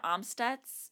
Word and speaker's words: Amstutz: Um, my handle is Sandra Amstutz. My last name is spelled A M S T U T Z.